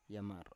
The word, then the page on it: yamar: Biak